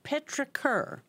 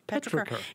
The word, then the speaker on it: petrichor: Petrichor. – We all know what that means. Yeah. – Petrichor. – Petrichor.